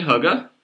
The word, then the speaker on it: Hygge: It's actually Hygge. – There's a pronunciation guide in the very beginning of the book. It's like on the first page, and it says Hygge.